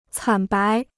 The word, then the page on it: 惨白: cǎn bái